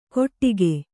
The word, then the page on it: ಗೊಟ್ಟಿಗೆ: goṭṭige